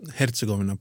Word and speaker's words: Herzegovina: Eh, det uttalas eh, Herzegovina på bosniska.